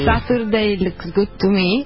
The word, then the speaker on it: Saturday Looks Good to Me: Saturday Looks Good to Me, l'ho detto bene? – È corretto quello che tu dici.